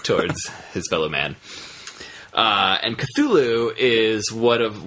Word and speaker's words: Cthulhu: I've heard it's pronounced Cthulhu now. – Cthulhu. – A little more S to it.